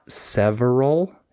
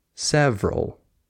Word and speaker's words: several: Like, it's not several, it's several. You just skip the syllable.